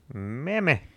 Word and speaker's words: Mamie: Her first name is M-A-M-I-E. Mammy? – Mammy.